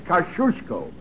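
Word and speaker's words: Kościuszko: In Poland, we say his name Tadashish Kosciuszko. But in America, Tadashish Kosciuszko is fine. – Thank you, young lady. Tadashish Kosciuszko. How's that? – Very good. You say it like you're going to sneeze, and you got it.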